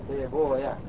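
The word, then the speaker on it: سيبويه: فالحاصل أن يقترى في هذا الباب أن يكون شغيفة ينطق على لغة الأصل، والمحدثون ينحون به نحو الفارسي فيقولون هو بضم ما قبل الواو سيبويا هكذا نفسويا سيبويا ونفسويا رابويا وسكونها وفتح الياء وإسكان لها فهي هاون على كل حال.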